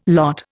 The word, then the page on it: lot: Indian